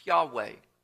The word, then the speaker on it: Yahweh: can be, or is sometimes pronounced, Yahweh.